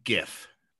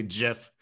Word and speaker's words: GIF: I know that GIF, the first word is graphic, so that's a hard G. So I say GIF, but I've heard plenty of people say GIF.